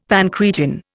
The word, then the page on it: pancreatin: Indian